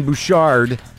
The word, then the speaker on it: Bouchard: So look out on Friday for Lauren, I'm going to pronounce it like it's French. Lauren Bouchard, creator of Bob's Burgers, co-creator of Home Movies.